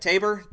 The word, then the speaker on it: Tabor: I'm going to go with cornerback Jalen Tees. How do you say that? Tabor.